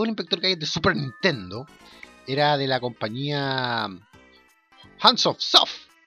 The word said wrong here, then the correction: Hudson Soft: en realidad nunca sabía cómo se pronuncia esa compañía. Hudson, Hudson Soft.